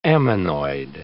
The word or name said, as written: ammonoid